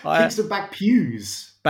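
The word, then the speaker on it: Kingston Bagpuize: Is it Kingston Bag? Poise or something. – Kingston Bag Pews.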